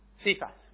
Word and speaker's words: Cephas: And how would you say, is it Cephas or Kephas? Cephas, with the, how you pronounce it.